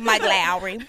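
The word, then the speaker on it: Lowrey: How all black people say his name. Mike Lowry. – And then like Lowry seasoning.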